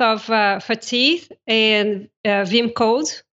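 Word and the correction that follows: vim-go: Vimgo, thank you.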